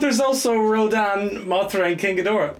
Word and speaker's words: King Ghidorah: Dora? – King... – Dora the Explorer. – King Ghidorah. Oh, you pronounced it wrong.